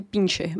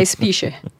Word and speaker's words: Spicher: Eles citam o doutor Michaels Fisher, se escreve S-P-I-C-H-E-R. Então, todas as vezes que eu vou ler, o meu cérebro me engana e eu, eu leio Pincher, mas não é. é Spisher como eu falei, né, um PhD, filósofo que lidera o laboratório de pesquisa de estética, que deve ser muito interessante.